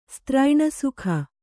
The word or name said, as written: ಸ್ತ್ರೈಣಸುಖ